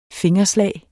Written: fingerslag

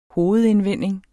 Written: hovedindvending

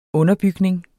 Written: underbygning

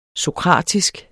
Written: sokratisk

